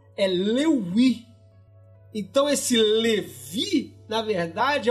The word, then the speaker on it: Lévi: Levi com, com um V pronunciado como se fosse U. Por isso que o Louis virou Levi.